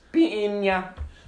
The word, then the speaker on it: piña: I have some horrible pronunciation blunders on this program sometimes. Yeah. Peeny Coladas. – Piña.